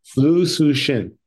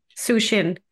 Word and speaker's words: Liu Cixin: Liu Cixin. – Cixin.